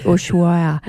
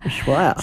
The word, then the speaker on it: Ushuaia: Flying to Buenos Aires and then down to Ushuaia. – I was going to say that because I couldn't remember how to say it properly. Yeah. Ushuaia. – Ushuaia.